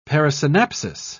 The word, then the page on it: parasynapsis: British English